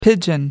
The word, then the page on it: pidgin: American English